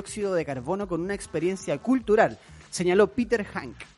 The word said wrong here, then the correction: Hanke: concejal, bueno, no sé si será, no, no es Hank, es Hanke, no sé cómo se pronunciará.